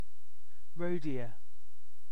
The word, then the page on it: chevreuil: France; /ʃə.vʁœj/